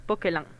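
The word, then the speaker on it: Poquelin: Se dice Jean-Baptiste Ponquelin.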